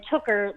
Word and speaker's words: Tooker: So there's two E's, two N's, and then Tooker, like hooker, but with a T.